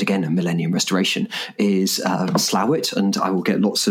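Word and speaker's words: Slaithwaite: I was thinking, I also Slathwaite. I've never known how to pronounce that. – Well, I think it's Slowit, but then if you talk to the people who really know the waterways there,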